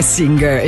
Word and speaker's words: Singer: in realtà la pronuncia sarebbe Singer in inglese.